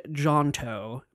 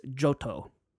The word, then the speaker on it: Johto: They're from a specific region, and I pronounced the region wrong, because I thought there was an N in it, so I said Johto, but it's Johto.